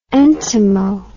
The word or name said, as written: entomo